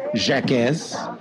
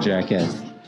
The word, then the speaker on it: Jaquez: Or if you were to pronounce my name phonetically, it'd be Uriah Jaquez. It's supposed to be French. If you were supposed to pronounce it in a French phonetic way, it'd be Jaquez. Yes, so like Jaquez.